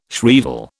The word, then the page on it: shrieval: British English